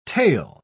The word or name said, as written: tael